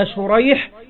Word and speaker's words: شريح: قال: الأول بالشين المعجمة والحاء المهملة اللي هو شريح، وهو تابعي يروي عن علي رضي الله عنه. والثاني بالسين المهملة والجيم اللي هو سريج، وهو من شيوخ البخاري.